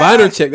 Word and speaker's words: Vaynerchuk: Vaynerchuk. That's how you say his name.